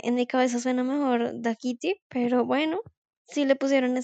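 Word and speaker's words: Dákiti: Eh, bueno, lo primero que tengo por decir es que está escrito, al menos en Spotify, con acento en la o sea que se pronunciaría Dakiti.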